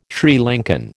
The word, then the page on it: Sri Lankan: American English